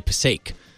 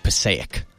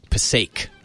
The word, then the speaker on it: Passaic: So I used to say Passaic, but I think it's more like Passaic. Passaic.